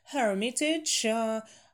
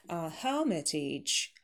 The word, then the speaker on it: Hermitage: И плюс еще связующий такой еле уловимый между ними. Hermitage, шва долгий. Как вот ее, допустим, если оставить местоимение her, her, her look, her book, her name, также и Эрмитаж her, только долгий. Hermitage, Hermitage.